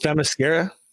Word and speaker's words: Themyscira: Is it Themyscira? Themyscira? Kira? – Themyscira? – Yeah, that's the one.